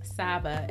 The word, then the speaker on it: Saba: Saba, S-A-B-A, or Saba. I've been saying it wrong.